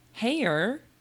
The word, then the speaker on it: Haigha: Haier is spelled H-A-I-G-H-A. Those were Carroll's words in the text when I parenthetically said he pronounced it so as to rhyme with mayor.